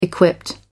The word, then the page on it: equipped: American English